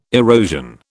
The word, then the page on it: erosion: British English